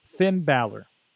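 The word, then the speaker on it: Finn Bálor: Finn Balor.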